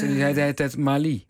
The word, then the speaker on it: Mali: En we gebruiken dus ook de Nederlandse uitspraak bij andere voormalige kolonies van Frankrijk, zoals Mali, niet Mali.